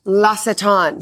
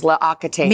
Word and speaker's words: L'Occitane: Let me pronounce this right in French. – Oh. – Oh, you could do it. – L'Occitane. – Yeah. – I mean, I went years thinking it was L'Occitane.